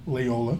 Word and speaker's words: Loyola: How do you say it? Loyola? – Loyola.